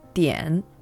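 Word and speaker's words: dian (点): In Chinese, it's pronounced dian.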